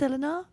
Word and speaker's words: Eleanor: I'm like, oh, God, don't you know my name's Eleanor? – Eleanor? – Eleanor, hey, now you're sober. You pronounce the O-R.